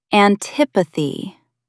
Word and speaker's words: antipathy: Put the stress on the second syllable. Antipathy. Try saying it. Antipathy.